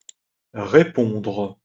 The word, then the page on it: repondre: French